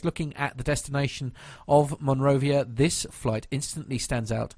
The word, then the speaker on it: Monrovia: However, this has now changed, and the Irish low-cost carrier today flew a Boeing 737-800, I should say, to Liberia's capital in, is it Monrovia? – Monrovia.